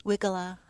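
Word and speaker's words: Weigela: There's so many ways to pronounce that. But I say Wajilla. – Wiggala.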